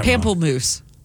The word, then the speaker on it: Pamplemousse: Pampaloose. – Is that it? – Is that what it is? – I would have gotten that Pamplemousse.